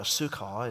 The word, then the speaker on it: Sychar: And he comes to a city of Samaria called Sychar, or Sukkah, as it's pronounced here, near the parcel of land which Jacob gave to his son Joseph.